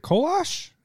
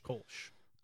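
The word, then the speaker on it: Kölsch: Is it Kolosh? Is that how you say it? – No, Kolsch? – Kolsch. – Kolsch. Kolosh. Yeah, there's no. – I learned how to say it from my dad, who is not a wordsmith. – Well, a wordsmith. – Tusami. – I don't know why I said Kolosh, because there's no O after the L, okay, so the reading is not my...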